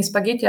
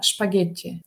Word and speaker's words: спагетти: Или спагетти говорят не спагетти, а шпагетти, да, потому что по-немецки это говорят.